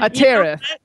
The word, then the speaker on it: Atereth: It's Atereth. And Atereth. – I had a fabulous DJ tell me how everybody can remember it. Ready? – Uh-huh. – Atereth, like tearing up the charts.